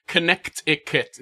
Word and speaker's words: Connecticut: It's Connecticut. There's a T in there for some reason.